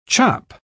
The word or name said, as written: chop